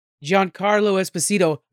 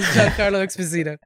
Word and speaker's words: Giancarlo Esposito: And this one has Giancarlo Esposito. I said his name right. I actually practiced that freaking name. As Giancarlo Esposito.